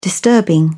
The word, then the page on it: disturbing: British English